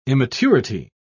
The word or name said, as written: immaturity